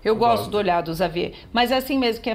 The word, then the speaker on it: Xavier: E em francês se escreve Xavier, mas fala o X ali tem som de Xavier.